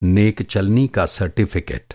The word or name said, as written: नेकचलनी